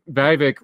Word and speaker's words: Vivek: So you're going to have at least Vivek, rhymes with snake, rhymes with fake.